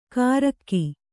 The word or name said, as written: ಕಾರಕ್ಕಿ